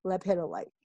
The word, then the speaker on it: lepidolite: And the reason why I chose that one first, and it's spelled L-E-P as in Peter, I-D, O-L-I-T-E, lepidolite. – Yeah, I'm glad you spelled it. Yeah. I wouldn't have gotten it. – No, I didn't either when I first saw it. So, right. But lepidolite has lithium in it.